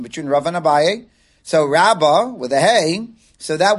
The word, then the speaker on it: Rabba: And when we say the one with the Hey, it's Rabba.